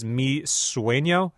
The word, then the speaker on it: Mi Sueño: Ziggy Dreams in Color is the album, and this song is Mi Sueño. Am I saying that right? – Correct.